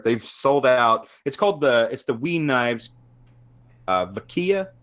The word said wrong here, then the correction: Vakita: I think it's Vakita. – Vakita. – Yeah, I think there's a T like in there. – Like Chiquita, but Vakita.